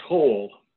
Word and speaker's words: Hohl: It's pronounced Hole.